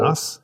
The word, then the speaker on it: Derimais: But it's just D-E-R-I-M-A-I-S. You say that then, Mr. Smarty Ball. – Dermas. – Eh? – Dermas. – D-E-R-I-M-A-I-S.